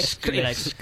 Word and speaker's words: Skrillex: Hay un montón de cameos, tanto a personajes de videojuegos como ha dicho Aymar, a Skrillex. No sé pronunciar. – Skrillex.